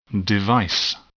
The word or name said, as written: device